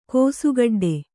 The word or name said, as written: ಕೋಸುಗಡ್ಡೆ